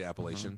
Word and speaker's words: Appalachian: That's how they say it in. The Appalachian region. – They say Appalachian.